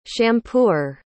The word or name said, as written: shampooer